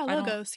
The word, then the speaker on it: Logos: Logos or Logos. – Oh, yeah. Logos.